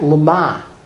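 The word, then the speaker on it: lama (לָמָה): The Hebrew, lamed mem hey. Pronounce that lama.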